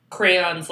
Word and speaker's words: crayons: I say crayons like crowns. That's how I pronounce it.